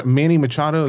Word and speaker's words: Machado: Is that Manny Machado? Is that how you pronounce it? – Mm-hmm.